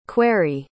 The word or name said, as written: querry